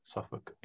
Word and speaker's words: Suffolk: Suffolk, England.